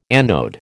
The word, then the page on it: anode: American English